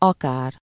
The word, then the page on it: akár: /ˈɒkaːr/